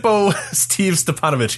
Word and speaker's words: Stipanovich: How do you actually pronounce his last name? I don't know if I know. Stipanovich? Is it just that easy? – I'm 99% sure it's Stipanovich, yes.